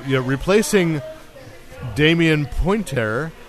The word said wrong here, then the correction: Poitier: From Poitier.